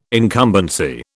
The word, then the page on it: incumbency: British English